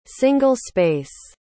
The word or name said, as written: single-space